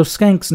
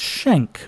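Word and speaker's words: Schenck: Joe Skenk's name is spelled as if it would be pronounced Schenk, but I have it on good authority that it's actually Skenk.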